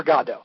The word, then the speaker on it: Godot: But technically Samuel Beckett says it's waiting for Godot. So just so for those of you who are familiar with it, it's waiting for Godot. – And that's spelled G-O-D.